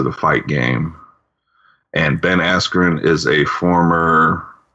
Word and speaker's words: Askren: Jake Paul, Ben Askren, is that how you say his name? – Yep, even right. Though the announcer announced his name, Askew.